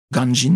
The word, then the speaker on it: Ganjin: Ganjin, n'est-ce pas, prononcé en japonais, et qu'ils l'ont ramené au Japon.